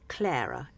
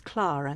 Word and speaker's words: Clara: Clara. Everybody says Clara now, but the English pronunciation, certainly in the 50s, 40s and 50s, was Clara.